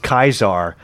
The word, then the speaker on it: Caesar: It's Caesar is his name, but it's pronounced Kaiser, because that's how they pronounce the name that they... So his name is Kaiser in the...